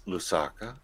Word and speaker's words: Lusaka: He got himself invited to one of the conferences in Lusaka. What was that? – Lusaka, yeah.